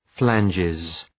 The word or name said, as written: flanges